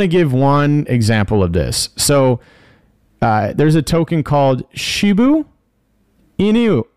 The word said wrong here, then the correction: Shiba Inu: Or no, I'm sorry. Shiba Inu. Shiba Inu.